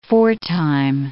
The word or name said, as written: foretime